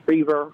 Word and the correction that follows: Shreve: I want to pronounce his name right. – Shreve. – He had said, yeah, Shreve, that's right. One syllable, not two.